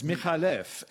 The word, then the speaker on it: McAuliffe: McAuliffe is actually an Arabic name, and it's Michalef, and the C is just for spelling pronunciation.